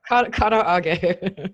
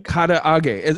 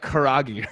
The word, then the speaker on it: karaage: uh, I have no idea that I've been pronouncing katarage wrong all these years. – Wait. Karaage. – Karaage. Because like I think I've always like said like karagi or something along those lines.